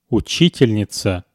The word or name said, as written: uchitelnitsa